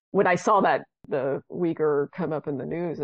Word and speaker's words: Uyghur: And yeah, actually, he would write about the, I might be about to pronounce this wrong, the Uyghur population. – Uyghurs, yes.